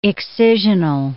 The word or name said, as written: excisional